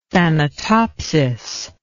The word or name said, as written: thanatopsis